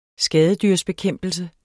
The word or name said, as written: skadedyrsbekæmpelse